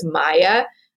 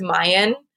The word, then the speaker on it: Maya: And he says something about thinking he was Maya. And he doesn't even say it right. He says Mayan, which is the language.